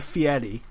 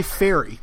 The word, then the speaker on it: Fieri: But whenever somebody says Guy Fieri like that, he's like, yeah. Oh, not many people pronounce it correctly. That's not his real name. It's Guy Fieri.